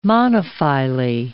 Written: monophyly

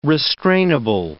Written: restrainable

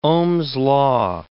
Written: Ohm's law

